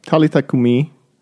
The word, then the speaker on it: Talitha kumi: Is that correct pronunciation? – Talitha kum. Yeah, Talitha kumi. – Nice. – There's usually an accent on the last syllable of Semitic words.